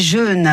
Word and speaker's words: jeûnes: Vous êtes jeune hein, ou vous faites euh, des jeûnes régulièrement. Euh, ah, très bien prononcé, exactement.